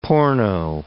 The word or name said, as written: porno